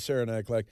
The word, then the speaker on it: Saranac Lake: You were using it to go to, what is it, Saranac Lake? Is that how I say it? I've never been there. Is that the right pronunciation? – Yes, it is. Yeah, it's Saranac Lake.